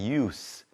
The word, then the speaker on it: use: 그리고 한 가지 더 알려드리면 동사일 때는 발음이 use, 이렇게 z 발음이 납니다. 하지만 명사일 때는 use, s 발음이 난다는 거 참고하시기 바랍니다.